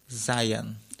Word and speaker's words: Zion: El monte Sion, este, ¿dónde está? – Zion. – Zion, perdón. – Zion se llama ahí Zion. Pero no sé cómo se pronuncia en castellano. Como, ok, esto viene de, ok, tengo la costumbre de llamarlo en inglés, como Zion, ok, sí.